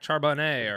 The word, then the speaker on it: Charbonnet: They drafted in the second round Zach Charbonnet, or I think I said that right. – Close enough.